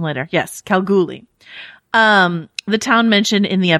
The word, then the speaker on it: Kalgoorlie: Oh, Kalgoorlie? – Well, there's an R in there, but okay.